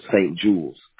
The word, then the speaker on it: St. Jules: J-U-L-E-S. Two different words. St. Jules.